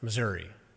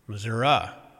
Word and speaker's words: Missouri: where it is not pronounced Missouri. It is pronounced Missouri.